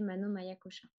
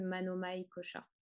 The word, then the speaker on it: Manomaya Kosha: Euh, je prononce tous les A, mais en vérité, on, n'est pas, on, peut ne pas prononcer le, dernier A de Maya. Peut-être que vous avez déjà entendu Mano Maya Kosha, c'est possible aussi.